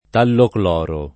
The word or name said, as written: tallocloro